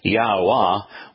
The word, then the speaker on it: Yahuwah: But the W is not pronounced. And the H in the name, the H is not pronounced because the H is the vowel ah. So it's Yahuwah, where the H supplies the ah sound and the W pronounce, supplies the O sound.